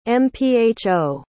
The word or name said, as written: mpho